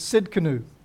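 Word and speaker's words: Tsidkenu: And I looked at all the different pronunciations thinking surely it can't be. But it is. It's Sid Canoe.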